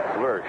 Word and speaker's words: Lersch: Name is spelled L-E-R-S-C-H. Lurch, Barry Lurch.